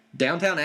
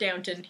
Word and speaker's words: Downton: Downtown Abbey has, you know, like 15 people working in it. – For reference, does in fact know it's pronounced Downton.